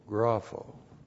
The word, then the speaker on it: grapho: G-R-A-P-H, long O, grapho.